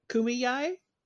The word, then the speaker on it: Kumeyaay: And the guy who I watched, he was a little bit older, and he feels like people emphasize the A in the middle too much. So, yeah. – Kumeyaay.